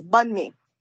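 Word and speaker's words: bánh mì: And that's the way it's pronounced, bun mi, like bun, hamburger buns, bun mi. – Bun mi. The other way to pronounce it is the North Vietnamese way.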